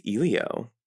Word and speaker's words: Elio: The next Pixar film is Elio. I hope I'm pronouncing that right. – I think you are.